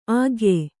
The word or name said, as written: ಆಖ್ಯೆ